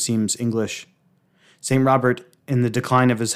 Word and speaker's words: Rodbert: St. Rupert, or Rodbert, pronounced Robert,